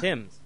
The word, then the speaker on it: Thames: Thames. – Thames? – Yeah. – Well, why isn't it spelled T-I-M-S then? – Because is this some kind of British pronunciation? – It is, yeah.